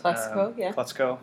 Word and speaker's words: Calexico: You know, so they're testing it out, and some of the bands that will be playing are like Death Cab for Cutie, Calexico, I think I'm saying that right. – Calexico.